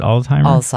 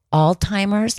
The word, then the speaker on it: Alzheimer: Alzheimer. – How do you pronounce it? Alzheimer. – But most people say Alzheimer's, and that's fine. That's fine if you want to pronounce it that way.